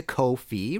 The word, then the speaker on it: Ko-fi: If you liked this show and want to support future episodes, please go to Ko-fi, rhymes with no fee, and support me with a coffee or a pint.